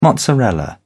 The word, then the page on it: mozzarella: British English